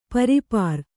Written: ಪರಿಪೂರ

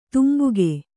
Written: ತಂಬಾಕು

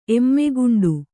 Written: ಎಮ್ಮೆಗುಂಡು

